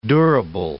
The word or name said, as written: durable